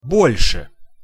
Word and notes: bol-se: Russian

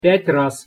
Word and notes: pjat- raz: Russian

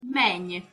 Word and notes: meny: Hungarian

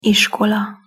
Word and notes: iskola: Hungarian

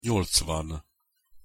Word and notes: nyolcvan: Hungarian

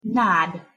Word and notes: nad: Hungarian

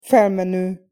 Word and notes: felmeno: Hungarian

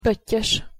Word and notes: pottyos: Hungarian

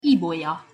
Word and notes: ibolya: Hungarian

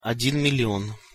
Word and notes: odin million: Russian

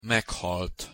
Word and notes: meghalt: Hungarian

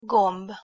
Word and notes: gomb: Hungarian

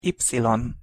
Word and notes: y: Hungarian

